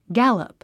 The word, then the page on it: gallop: ɡǽləp; ギャロプ